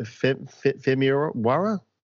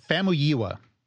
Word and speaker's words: Famuyiwa: Okay, so the rumor is that Rick, how do you pronounce that? Femiwara? – No, I don't think that's close. – I'm horrible. So, what is it? – Famuyiwa, I think. – Okay, that's closer than I was getting.